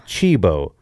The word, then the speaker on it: Cibo: But we tried to go there, 5-hour wait, so we went to Cibo, C-I-B-O, but we confirmed it because we were there last night with your family. It is pronounced Chibo, and that's fine.